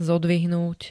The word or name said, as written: zodvihnúť